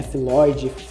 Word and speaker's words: Floyd: É Floyd? Eu nunca, nunca sei como pronunciar esse nome. É Floyd, Floyd. Tem vários, vários, várias formas de, de pronunciar.